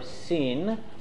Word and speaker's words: Sin: This is section Shin or Sin. It's pronounced both ways.